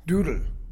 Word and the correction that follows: dudy: Dudy. – Doodlesack. – No, not doodle. – Okay. – Dudy.